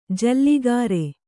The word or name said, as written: ಜಲಗಾರ